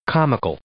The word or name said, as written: comical